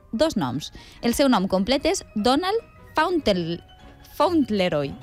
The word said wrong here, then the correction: Fauntleroy: Ui, no ho va pronunciar molt bé. – Fauntleroy, sí, sí, ho has dit. – Fauntleroy.